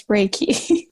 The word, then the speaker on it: Reiki: So it's Reiki. – Yes. – Correction to me. – You're saying it just fine.